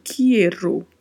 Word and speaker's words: quiero: Muita atenção à pronúncia, porque este i precisa ser pronunciado. A gente não pode se confundir com o português, que a gente fala quero, né? Então tem um i aí que precisa ser pronunciado, quiero, quiero ele,